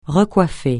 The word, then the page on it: recoiffer: francais